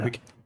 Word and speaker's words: anaerobic: And then you have, I struggle to pronounce it, aerobic. – Stamina. Anaerobic. Anaerobic? – Yeah. Yeah, anaerobic. A word, yep.